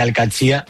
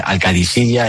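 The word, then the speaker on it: Al-Qadsiah: Bueno, se suele decir Al-Katzia, pero también hay gente que dice Al-Kadisiyya, es que es difícil, ¿eh? Ah. No es fácil, pero Al-Katzia es lo más fácil.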